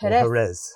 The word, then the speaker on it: Jerez: So we went to, I pronounce it Hooray. – Jerez. – Thank you. Jerez.